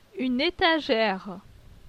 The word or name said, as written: Une etagere